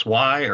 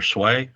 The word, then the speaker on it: swai: It's swai or sway. – Oh, okay. Mm-hmm. – I've heard it pronounced both ways.